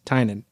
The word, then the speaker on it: Tynion: If you like scary comics, anything by James Tynan, and his last name is spelled T-Y-N-I-O-N, and so his production company is called Tiny Onion, and he has like makes comics and stuff.